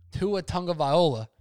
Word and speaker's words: Tua Tagovailoa: Your boy, Tua Tagovailoa, did I say that right? – You did.